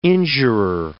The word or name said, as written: injurer